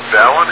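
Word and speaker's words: balun: Okay, and any comments about the appropriate pronunciation of the term ballon? – Nope, ballon is the proper pronunciation. – Yeah, what I found, I think it's a fair mistake for people to make. You know, we think of balance, unbalanced, hence we say ballon.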